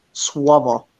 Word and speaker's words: słowo: The word slave is actually słowo. Okay. So if you were to phonetically spell it, it would be S-W-O-W-O, słowo, or V-O, right?